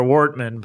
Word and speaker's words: Wortman: Do you pronounce it Vartman? – No. I pronounce it like a West Sider, Wortman.